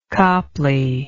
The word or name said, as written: copley